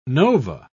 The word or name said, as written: nova